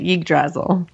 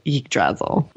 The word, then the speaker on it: Yggdrasil: Oh, I've always called it Yggdrasil. – That works for me. That's one of those words where every time I'm like, not today, Satan. No, I'm not pronouncing it. You don't hear it spoken out loud that often. So like, as far as I'm concerned, whatever pronunciation you want to use is probably wrong. And until an ancient Norseman comes and corrects me, I will defend my pronunciation of Yggdrasil.